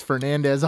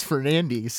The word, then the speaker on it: Fernandes: I think it's Fernandez. All of a sudden, it's Fernandes.